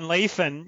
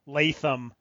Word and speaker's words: Lathan: I thought it was Latham, like Larry Latham. – That's what I wrote down. – Yeah, but actually it's with an N at the end, not an M.